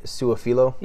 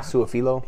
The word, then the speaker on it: Su'a-Filo: All right, so we'll move on to uh, Cincinnati, and they picked up uh, offensive guard from Dallas, Xavier Sua Feel. Feel uh, uh, I butchered his name. Uh, to a three-year deal. Xavier Su'a-Filo. – Yeah. Su'a-Filo. Yeah.